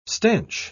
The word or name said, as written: stanch